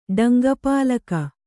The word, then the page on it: ಡಂಗಪಾಲಕ: Kannada